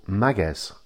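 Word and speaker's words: Máguez: So instead of being Magues, as a lot of people say, you put the emphasis on the first syllable, so it's Magues, Magues. And remember, again, that Z is pronounced as an S.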